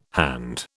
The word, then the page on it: hand: British English